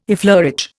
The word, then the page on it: effleurage: Indian